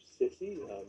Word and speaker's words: SSCI: SSCI, which is commonly abbreviated into SISI, just sort of a pronunciation, released a 1,000-page report.